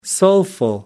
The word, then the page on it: soulful: American English